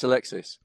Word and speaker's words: Alexis: Yeah, it's Alexis.